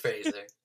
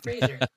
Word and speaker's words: Fraser: It's just Fraser.